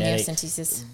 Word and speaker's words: amniocentesis: genetic and amniocentesis. – One of those. Yeah. I think it was the second one. Yeah. Amniocentesis. – Uh-huh. – Did I say that right? – Uh-huh. Sure did. Sure did.